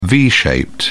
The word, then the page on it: v-shaped: British English